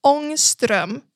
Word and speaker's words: Ångström: So no, the A with the circle above it is pronounced totally differently. It's pronounced like an AO sound. So it's Ångström, because then the two dots above the O also makes it a OE sound.